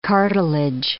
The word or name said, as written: cartilage